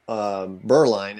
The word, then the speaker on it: Berline: So my last name is Berline is how you pronounce it. I think Berline. I should just take the E out and people would figure that out.